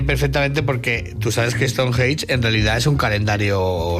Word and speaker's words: Stonehenge: Que tengo mi propio calendario, sí, pero hombre, rodea siempre un halo de misterio a Stonehenge.